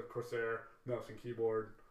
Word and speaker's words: Corsair: They were actually just bought out by Corsair. Is that how you say it? – Corsair.